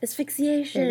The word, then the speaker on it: asphyxiation: How do you say it? Asphyxiation. – There you go. Asphyxiation.